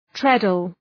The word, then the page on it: treadle: ‘tredəl